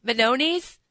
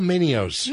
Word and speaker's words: Minio's: And then on the 21st at 6:30, Paul, how do you pronounce that? – I want to hear your version first. Come on. – Minoni's? Minoni's? – I adore you. Paul Minio's. – Minio's. All right, then.